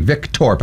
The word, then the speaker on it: Viktorp: V-I-K-T-O-R-P. Vic Thorpe. I said it wrong. – Oh, it's Vic Thorpe. Thorpe. – I had an H and there's no H.